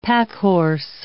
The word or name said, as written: packhorse